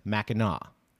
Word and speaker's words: Mackinac: It's spelled that way, but pronounced the other way. – Yes, it's spelled Mackinac, but it's pronounced Mackinac.